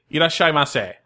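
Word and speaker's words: irasshaimase: Can you say properly? – Absolutely. Irashaimase. – Okay. – So that's. Yeah. – It's like five syllables.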